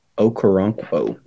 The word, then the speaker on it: Okoronkwo: Okoronkwo. I said it right.